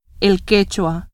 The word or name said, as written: quechua